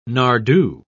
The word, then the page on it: nardoo: British English